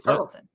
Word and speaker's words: Tarleton: Is it not Tarleton State? – Tarleton. – It's just Tarleton? Okay. – Tarleton State. I was my problem was with your pronunciation of the word Tarleton. – Oh, I thought it was like a charlatan.